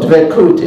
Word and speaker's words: Dvekut: But anyway, so Dvekut is the proper Sephardic Israeli pronunciation. And Dvekut is, I guess it would be written in English, D-E-V-E-K-U-T.